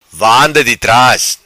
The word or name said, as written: vanda di tras